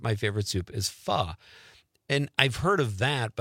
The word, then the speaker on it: pho: Are we saying that right, Conrad? Is it pho? – It's pho. – Pho.